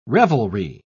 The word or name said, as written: revelry